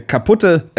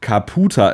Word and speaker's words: Caputher: Beim ersten Lesen dieses Vereins könnte man denken, das ist jetzt nicht so ein förderlicher Vereinsname, denn das ist der kaputte SV. Aber nein, er wird natürlich nicht so ausgesprochen, sondern er wird ausgesprochen Kaputa SV, geschrieben mit C-A-P-U-T-H-E-R.